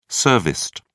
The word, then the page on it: serviced: British English